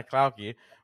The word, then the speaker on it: Klaucke: Suzanna Klaucke, one of our previous winners.